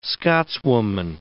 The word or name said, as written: Scotswoman